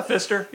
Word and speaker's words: Pfister: Is Pfister? is that how you pronounce it? I think it's German. – Yeah, Pfister. Yes. Pfister. Right.